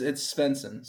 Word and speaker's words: Svensson: Yeah, no, it's Svensson. So this sounds okay. Exactly what it looks like, but kind of a weird one.